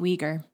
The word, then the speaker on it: Uyghur: Uyghur.